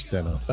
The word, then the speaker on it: Denon: We have an amazing Denon. Is that how you say it? – Yes, Denon.